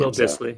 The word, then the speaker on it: Dissly: I don't know how to say his last name. – Will Disley. So. – Disley, yeah, okay, there it is. I'll throw to you, since you know how to pronounce his name.